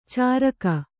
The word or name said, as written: ಚಾರಕ